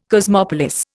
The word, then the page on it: cosmopolis: Indian